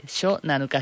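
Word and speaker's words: Shonanuka (初七日): 7 días después de que fallezca la persona, se hace una ceremonia que se llama, lo voy a pronunciar fatal, tú me corriges, mi joco, se llama Shonanuka. – Sí, Shonanuka. Sho es de primeros y Nanuka es de 7 días.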